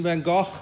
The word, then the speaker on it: van Gogh: Probably my most favorite artist, Vincent van Gogh, or Van Gogh, as Americans say. I'm Afrikaans, so Afrikaans is a Dutch derelict. It's Gogh, Vincent van Gogh.